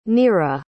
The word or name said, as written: nearra